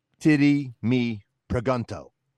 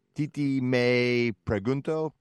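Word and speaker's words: Tití Me Preguntó: So in Ohio, they would say, titty me pregunto. – Right. – But that's not how it's pronounced, right? Because it's Spanish. So it's more like something like titi me pregunto.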